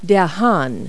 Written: rooster

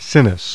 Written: Sinis

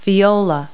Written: viola

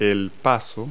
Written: pass

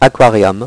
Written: aquarium

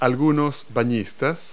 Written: swimmers

